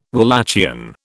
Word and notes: Walachian: British English

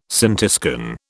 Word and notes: scintiscan: British English

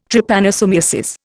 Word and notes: Trypanosomiasis: Indian

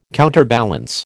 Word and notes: counterbalance: American English

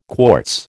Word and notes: quartz: American English

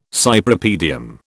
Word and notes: Cypripedium: British English

